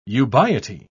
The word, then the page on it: ubiety: British English